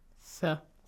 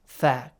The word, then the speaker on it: ث: ثم بعثناكم, two letter ثا, they have to be pronounced ثا. – Tha, correctly, yes? – Yes? – The way we say tha, bite your tongue a little.